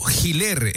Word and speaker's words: Giler: Una sola cosita, el apellido no es Hiller, el apellido se pronuncia Hiller. Es un apellido bastante conocido. Es como también dicen Luzurraga, Luzárraga. Y repito, Giler es el apellido.